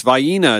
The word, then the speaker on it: Vienna: learn how to pronounce Vidalia. It's Vienna, it's not Vienna.